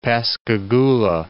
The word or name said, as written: Pascagoula